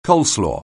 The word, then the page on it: coleslaw: anglais